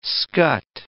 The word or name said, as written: scut